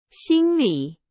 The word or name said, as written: 心理